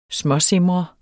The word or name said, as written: småsimre